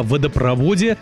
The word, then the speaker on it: водопровод: А теперь о водопроводе, газопроводе, трубопроводе, нефтепроводе, мусоропроводе и прочих проводах. В таких сложных словах, то есть где есть два корня, ударение падает только на последний слог.